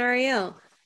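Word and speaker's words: Arielle: Hi, my name is Christina Ariel. That's spelled K-R-Y-S-T-I-N-A. A-R-I-E-L-L-E.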